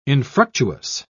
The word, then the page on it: infructuous: British English